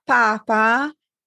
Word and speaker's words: papa: La parola Papa spesso viene confusa dagli studenti con la parola Papa, che ha un accento sulla ultima A.